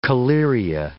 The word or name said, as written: collyria